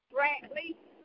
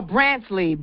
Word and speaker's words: Brantley: You say his name is J.B. Brantley? – Brantley. – Brantley. – B-R-A-N-T-L-E-Y. – Oh, Brantley. Brantley. B-R-A-N-T. Yes. Okay, got it.